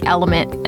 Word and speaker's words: LMNT: like Element, and that's spelled L-M-N-T.